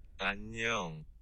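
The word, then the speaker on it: Annyeong (안녕): Annyeong. Annyeong. – That don't even sound like anything I would know. Yeah. – How'd she say it? – Annyeong.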